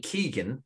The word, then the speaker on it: Keoghan: Barry Keoghan is that guy's name. The second name Keegan, very common, incredibly common Irish second name.